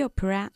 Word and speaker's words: opera: Fred saw the opera three times. – Okay, the opera. Dos sílabas, okay? Opera.